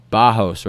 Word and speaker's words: Barros: So it's like spelled Barros, but it's said Bajos, right? – Bajos, yeah.